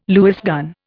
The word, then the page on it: Lewis gun: Indian